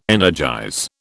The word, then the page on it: energize: British English